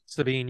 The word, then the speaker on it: Sabine: Sabine, yeah.